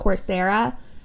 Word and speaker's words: Coursera: There's, I think it's pronounced Coursera, and then there's edX, which is ED and then X.